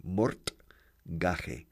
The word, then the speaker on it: mortgage: Se escribe mortgage, como suena. Mortgage. Pero se pronuncia diferente, claro, como siempre en inglés, mortgage. More, como más, more, mortgage.